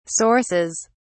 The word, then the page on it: sources: British English